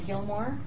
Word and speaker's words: Gilmour: Donna, I am Donna Gilmore, G-I-L-M-O-U-R.